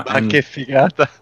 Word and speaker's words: ma che figata: Ma che figata. – Ma che figata. That's how you say it. It's like Italian for like, oh my god, so fucking cool.